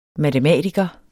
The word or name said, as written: MATEMATIKERE